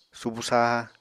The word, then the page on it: subu saha: Apatani